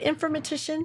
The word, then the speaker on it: informatician: Did I like say that right, informatician? – You did. And Yahoo! – Actually, that's half the battle, just being able to pronounce the word informatician.